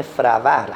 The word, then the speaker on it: فروهر: خودشون میگن این فروهر در حقیقت خب تلفظ امروزیشه، تلفظ درستش در واقع فروهر هست، یعنی روی فه باید ساکن گذاشته بشه.